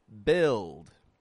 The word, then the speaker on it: build: The following word also has spelling that does not reflect the pronunciation. It is the word build. Build. B-U-I-L-D.